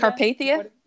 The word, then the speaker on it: Carpathia: carpathia yeah but uh, in this, it tells that the captain of that gave up his um, his room for her.